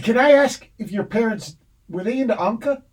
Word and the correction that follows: Enka: Oh, Enka. – Sorry. Enka. Bad pronounce.